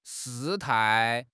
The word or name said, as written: 事态